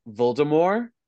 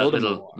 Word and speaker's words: Voldemort: The first four audiobooks, he's giving it like a little French, like that Voldemort. – Voldemort. – Yeah, like you don't pronounce the T kind of a thing.